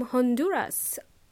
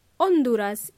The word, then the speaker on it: Honduras: But before we begin, I want to say hello to Edwin from Honduras, or Honduras in Spanish, because in English we pronounce the letter H.